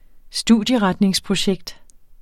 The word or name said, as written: studieretningsprojekt